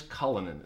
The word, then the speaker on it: Cullinan: Rolls-Royce Cullinan. Is that how you pronounce it? – Yes.